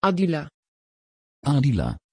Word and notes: adila: Dutch

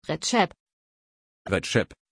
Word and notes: recep: German

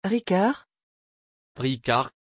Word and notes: Ricard: French

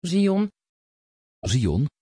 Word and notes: Zion: Dutch

